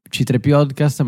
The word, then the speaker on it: c3.p0podcast: Quindi si legge C3Podcast, ma è scritto in questa maniera perché anche ci hanno tra l'altro fregato alcune lettere, alcune cose, quindi va bene.